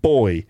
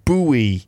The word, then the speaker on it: buoy: So a buoy in British English. And it's just pronounced all wrong and weird in American English, like buoy. Like, what's that? Well, like David Bowie or something?